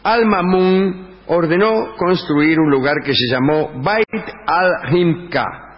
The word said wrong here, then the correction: Bait al-Hikma: Lo dije mal. Bait al-Hikma. Bait al-Hikma.